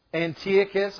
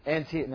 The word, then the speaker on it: Antiochus: Antiochus. – I like that. It's good. You're pronouncing it right now. It sounds good. And Antiochus. No, I learned that. Antiochus Epiphanes the Fourth.